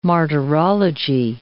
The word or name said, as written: martyrology